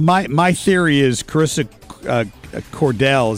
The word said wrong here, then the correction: Codel: Is that did I say the name right? Carissa Codell, C-O-D-E-L, is going to, her career is going to blow up over this.